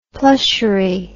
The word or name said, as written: plushery